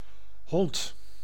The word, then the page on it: hond: [ɦɔnd]